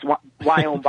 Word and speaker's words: Viome: So what we do at Viome, which is V-I-O-M-E, and again, don't ask me how to pronounce V. It's just in Indian, it's Viome, Viome.